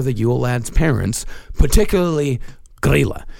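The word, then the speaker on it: Grýla: These I know I'm actually pronouncing right. – Okay. – There is a Grilla and Lepaluthe. – Huh. – Yeah. Grilla and Lepaluthe.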